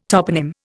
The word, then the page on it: toponym: Indian